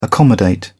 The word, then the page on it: accommodate: British English